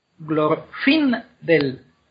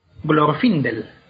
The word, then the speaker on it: Glorfindel: Pero glorfindel. Glorfindel. Fin es la segunda sílaba y termina en consonante. O sea que es fuerte, o sea que se lleva la, el acento. Glorfindel. – Vale. Por eso no es Glorfindel.